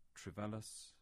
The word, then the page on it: Trevellas: English